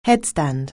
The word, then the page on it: headstand: anglais